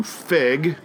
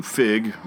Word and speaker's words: feague: The definition for the verb to fig, a.k.a. to fig, F-E-A-G-U-E is how it is spelled.